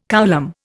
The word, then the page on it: coulomb: Indian